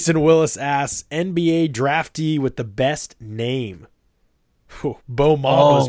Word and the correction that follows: Mo Bamba: is pretty good. Mo Bamba. – No, it's Mo Bamba.